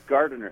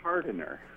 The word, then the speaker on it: Gardiner: but they pronounced Gardiner as Hardiner.